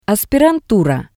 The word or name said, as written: aspirantura